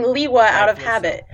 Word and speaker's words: Lewa: I pronounce the green and lime green colored Toa of Air as Lewa.